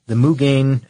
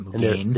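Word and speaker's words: Mugen: It was the Mugane, and I'm saying that right. I know I looked it up. The 2008 Honda Civic Si Sedan Mugane Edition, and it's M-U-G-E-N, that's how you say it, Mugane.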